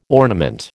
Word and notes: ornament: American English

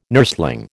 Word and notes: nursling: American English

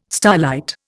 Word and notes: starlight: Indian

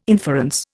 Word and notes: inference: Indian

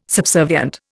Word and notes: subservient: Indian

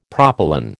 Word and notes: propylon: American English

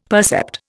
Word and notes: percept: Indian

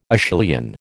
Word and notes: Acheulian: American English

